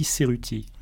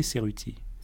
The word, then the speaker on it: Cerutti: D'ailleurs, je dois vous remercier, Alex, parce que vous avez parfaitement prononcé mon nom. Vous avez dit Cerutti. À Marseille, on m'a toujours appelé Cerutti. C'est quand je suis venu à Paris pour faire mes études que j'ai entendu mon nom un peu écorché, certains m'appelant Cerutti ou Cerutti, et ne prononçant ni à la française ni à l'italienne.